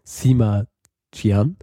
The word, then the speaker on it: Sima Qian: Und das ist ähm, im zweiten Jahrhundert vor Christus von einem gewissen Sima Xian und seinem Vater verfasst worden. Ja, ich, wahrscheinlich habe ich es völlig falsch ausgesprochen, aber ich lehne mir jetzt aus dem Fenster und sage, heißt Sima Qian.